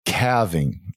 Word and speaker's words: calving: So what is calving? Am I pronouncing that right? – Yeah, you are pronouncing that right.